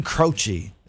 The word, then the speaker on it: Croce: Jim Croce, Jim Croce, Jim Croce is how you say it.